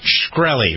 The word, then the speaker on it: Shkreli: Let's see over the weekend if more and more people pay attention to the story of Martin Shkreli. Remember him? Pronouncing it right? S-H-K-R-E-L-I.